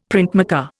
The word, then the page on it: printmaker: Indian